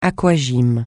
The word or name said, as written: aquagym